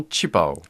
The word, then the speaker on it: 旗袍: Mais maintenant, on a tendance à utiliser directement sa prononciation chinoise, c'est-à-dire qu'on n'utilise plus robe fondue des deux côtés, on dit directement qi pao.